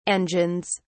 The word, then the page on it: engines: British English